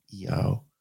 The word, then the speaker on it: IAO: Isis, Apophis, Osiris, E-A-O, which is I-A-O, but it's pronounced E-A-O.